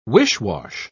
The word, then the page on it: wish-wash: British English